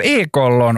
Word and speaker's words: ekollon: Och här, första ordet vi har är ju ekollon, och det skrivs med O, men man uttalar det med Å, för vi säger ju inte ekollon.